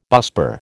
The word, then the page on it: busbar: American English